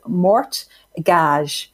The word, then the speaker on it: mortgage: They took it directly from Old French, where it was mort gage. So there is a silent T in mortgage.